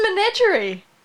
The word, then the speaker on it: Menagerie: How would you say that? – That's menagerie. – Are you sure? Really? That's a really weird. Okay. Menagerie, then.